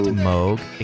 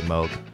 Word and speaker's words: Moog: It's pronounced Moog, excuse me. – Is it Moog? – It is actually Moog.